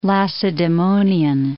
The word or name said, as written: Lacedaemonian